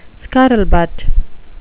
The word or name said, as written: scuttlebutt